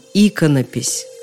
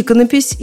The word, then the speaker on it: иконопись: Потому что стоило бы им знать, что слово, которое они прочитали на табличке при входе в зал, читается по-другому – иконопись. И иконопись, и еще раз иконопись. Так написано во всех уважающих себя словарях. Да нет, просто во всех словарях.